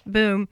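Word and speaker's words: BOEM: or the acronym basically is pronounced BOOM,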